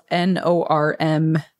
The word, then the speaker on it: Normman: And Norman is spelled N-O-R-M-M-A-N. So it's like Norm-man.